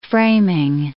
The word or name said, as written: framing